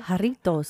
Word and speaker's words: Jarritos: about to open a grapefruit jarrito. I'm not saying it right, but jarrito. – Jarritos. – Jarritos.